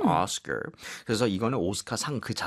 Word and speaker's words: Oscar: 끝까지 R 발음 강하게 해주셔야 돼요. 그래서 오스카가 아니고 Oscar.